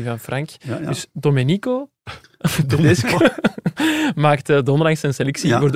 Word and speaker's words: Domenico: Donderdag maakte Domenico Tedesco zijn selectie voor de oefening. – Is het niet Domenico? – Wat zei je? – Domenico. – Domenico. – Ah ja, mijn uitspraak is soms nog...